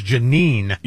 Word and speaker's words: Jeanine: Judge Jean Pirro? – Yeah, it's Jeanine Pirro. – I can't ever say her name properly. It's Jeanine. – Yes. – Not Jean.